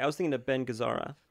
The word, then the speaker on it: Gazzara: Ben Gazzia. And Seymour Castle. Kissel. This is John Cassavetes. – I think it's Gazzara.